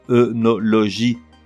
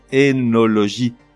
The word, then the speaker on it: œnologie: Le mot s'écrit, comme tu sais, O-E collé e N-O-L-O-G-I-E, et on le prononce œnologie Erreur, il n'en est rien. La bonne prononciation est énologie, comme s'il s'écrivait avec un E.